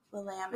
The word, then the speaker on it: Willamette: Willamette.